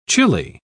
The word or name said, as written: chilly